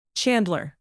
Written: chandler

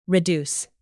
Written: reduce

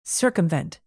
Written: circumvent

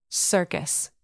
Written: circus

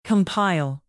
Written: compile

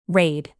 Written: raid

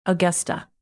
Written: augusta

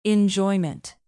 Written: enjoyment

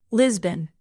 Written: lisbon

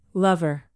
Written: lover

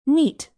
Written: neat

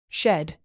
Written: shed

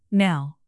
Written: now

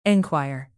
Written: enquire

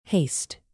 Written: haste

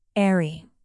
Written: aerie

